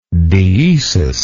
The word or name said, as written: deesis